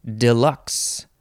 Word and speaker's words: deluxe: The first syllable is deluxe or deluxe. You could also say, what is this, deluxe? Deluxe, and maybe some other version of that. Deluxe, deluxe, and I can't think of any other way to say that vowel. Deluxe, foo, foot, deluxe, deluxe, maybe that's it. Deluxe, like L-O-O-K-S. But this is spelled D-E-L-U-X-E, adjective from 1819.